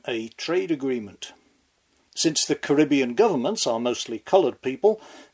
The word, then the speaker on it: Caribbean: First, let me just pat myself on the back for having finally schooled myself to give this word the American pronunciation, Caribbean. Back in Airstrip 1, we used to say Caribbean.